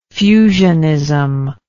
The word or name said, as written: fusionism